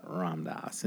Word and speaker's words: Ram Dass: I wish I was a better, you know, the Pete Holmes-esque where you do know about Ram Dass and you know you've read him and you've done.